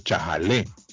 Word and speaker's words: chajalé: Coincidentemente, ya que es la época de los vientos, lo que le llaman, lo que llaman ron ron, es el chajale o chajalé porque le pone acento en la E, chajalé.